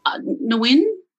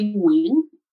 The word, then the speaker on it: Nguyen: Yeah, it's like a little wing. So the G always tricks everyone. It's G is silent. I'm happy to say it Nguyen, which is, whatever comes out of your mouth without the G, we're fine.